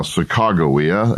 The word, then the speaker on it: Sacagawea: So you say Sacagawea, the standard national spelling and pronunciation is now Sacagawea,